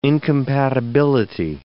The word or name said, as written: incompatibility